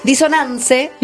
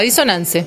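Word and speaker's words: Dissonanze: ¿Lo pronuncio bien? – Sí. – Porque sos vos la que habla italiano. – Disonance, con una sola dos S y con Z. – Y con Z, Disonance.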